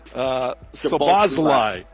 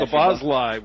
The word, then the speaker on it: Szoboszlai: the Hungarian Szoboszlai. – Yeah, Szoboszlai with the second goal for Liverpool.